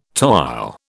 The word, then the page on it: toile: British English